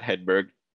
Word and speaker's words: Hedberg: So my Twitter handle is how would you pronounce my last name if you see it on your screen there? – I just say Hedberg. – Yeah. So yeah, that's basically my Twitter handle. So it's @Hedberg, spelled like H E Hedberg.